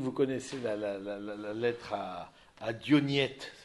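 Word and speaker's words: Diognète: Dioniette, comme ça se prononce, avec un accent grave.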